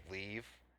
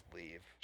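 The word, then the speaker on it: Liev: I think his name's Leave. It looks like it's spelled like Levi's Leave.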